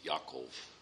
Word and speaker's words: Yaakov: Now Israel, that's the other name of Jacob, who is really pronounced Yaakov. That's how the Israelis pronounce that.